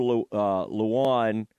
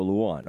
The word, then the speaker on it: Lewan: It's Taylor Lewan, right? How do you say it, Aaron? Lewan? Taylor Lewan? – You had it right the first time. – Oh, Lewan.